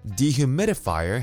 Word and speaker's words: dehumidifier: Dehumidifier has six syllables and the stress is on the third syllable.